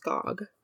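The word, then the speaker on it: Gág: We'll be looking into a little bit more about Wanda Gog, whose name is spelled G-A-G, but it's not Gag, it's Gog.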